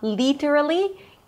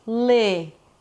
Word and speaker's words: literally: It's not literally, it's li. Li. Notice that the L is a little heavier. It's not super light. Li. Li. But a little heavier, you engage the back of the tongue. Li. Li. This is the first syllable is also the primary stress, so it's longer and higher in pitch.